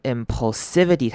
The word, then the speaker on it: impulsivity: 好，impulsivity 这个字要特别注意的是它的 impulsivity 它的第三个音节的 s 要念成短母音 i s s 的声音 impulsivity。